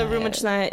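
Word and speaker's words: Rumichnaya (рюмочная): Meaning we only went to this specific type of establishment called a Rumichnaya. I'm saying it like completely American, but it's okay. – How do you say it in Russian? – Rumichnaya.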